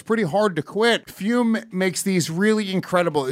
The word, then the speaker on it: Fum: That is spelled F-U-M, pronounced Fume.